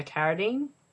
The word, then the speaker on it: Carradine: I'm probably pronouncing his name wrong, but... – Who, Carradine? – Yeah, Carradine. – Yeah, you're right.